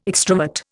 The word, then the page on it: extrovert: Indian